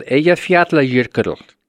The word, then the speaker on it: Eyjafjallajökull: The volcano is called Eyjafjallajökull. – Okay, repeat that. And then what does it mean? – Eyjafjallajökull.